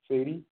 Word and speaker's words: Saidy: My last name is spelled S-A-I-D-Y, Sadie, B-A, Ba.